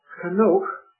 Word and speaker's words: Henoch: Instead of Enoch, you have the word Henoch, but it's the same. And why? I cannot tell you, because the Hebrew word is, and this is the literal pronunciation, Henoch, but at times, even the 1901 translates it Enoch, and at times it translates it Henoch.